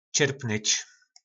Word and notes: Cyrpnyc: Po našimu

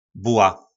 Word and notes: Bula: Po našimu

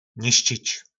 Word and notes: Niscic: nářečí Po našimu

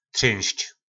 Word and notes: Trynsc: Po našimu

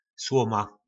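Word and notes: Sloma: nářečí Po našimu

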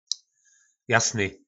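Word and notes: Jasny: nářečí Po našimu

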